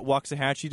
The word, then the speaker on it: Waxahachie: I think I got Waxahachie down. Did I say it right? – Mm-hmm. Yep.